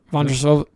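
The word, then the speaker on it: Vondroušová: Did I pronounce Vondrousova? – Vondrousova, yeah.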